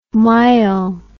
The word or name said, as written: myel